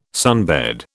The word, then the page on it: sunbed: British English